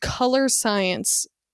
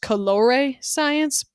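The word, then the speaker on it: Colorescience: Okay, so there's this brand called Color Science. I don't know why it's not pronounced Colore Science, because there's an E after the R.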